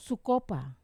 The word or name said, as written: kopa